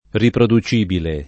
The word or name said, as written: riproducibile